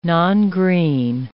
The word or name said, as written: nongreen